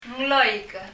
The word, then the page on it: nglóik: ŋlɔi(k)